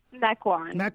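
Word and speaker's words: Mequon: From, and you're going to have to help me again with the pronunciation. I got that short-term memory thing going here. Maquon, yes. Wisconsin, is that it? – Maquon, yes.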